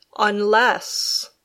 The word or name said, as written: unless